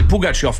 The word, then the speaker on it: Pougatchev: Puis pour répondre à ta question sur la prononciation des joueurs russes, quand on tourne ça à la Pougatchev, c'est tout simplement une tournure de phrase ou une prononciation américanisée. Parce que c'est Pougatchev qu'il faudrait dire.